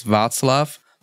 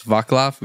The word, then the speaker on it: Václav: My like official legal first name is Václav. But everyone pronounces it as Vaclav, which I hate.